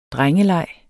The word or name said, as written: drengeleg